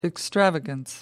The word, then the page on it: extravagance: American English